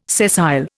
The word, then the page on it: sessile: Indian